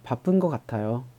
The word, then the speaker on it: 바쁜 것 같아요: 直接就读成了“ 帕 푼 거卡塔哟，帕 푼 거卡塔哟，帕 푼 거卡塔哟”。为什么？因为读这样读起来更加的自然一点，好听一点嘛，是不是？